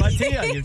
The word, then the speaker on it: Bhatia: Yeah, it's Bhatia. You nailed it.